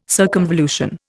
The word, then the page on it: circumvolution: Indian